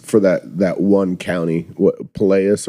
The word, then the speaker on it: Pinellas: Pinellas.